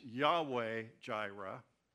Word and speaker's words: Yahweh: And I wanted to talk a little bit about this because um, you'll hear this as you study the Bible Yahweh, You've heard the term Jehovah Jireh or Jehovah Nissi, or, and, and really, it should be pronounced Yahweh Jireh, Yahweh uh, Nissi, or Yahweh Kadesh, holy, Yahweh uh, Shalom, peace.